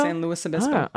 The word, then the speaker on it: San Luis Obispo: Multiple listeners wanted to make sure AG knows how to pronounce San Luis Obispo. Lewis rhymes with poopiss, not Louis rhymes with poopy.